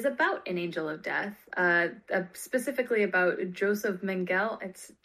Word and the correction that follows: Mengele: Okay, so his name is then pronounced Mengele.